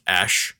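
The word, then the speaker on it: Æ: And then the symbol is pronounced Ash.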